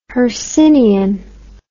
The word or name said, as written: hercynian